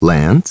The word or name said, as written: Lance